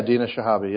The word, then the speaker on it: Dina Shihabi: with Dina Shihabi. Is that how you say it? – Yeah, Dina Shihabi.